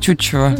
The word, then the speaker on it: Тютчев: Скинь мне текст Тютчева.